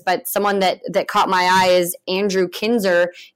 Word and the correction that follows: Kinsner: I say it right, Jen? – Kinzer. Close enough. – Kinzer. Kinsner. Andrew Kinsner. Dang it.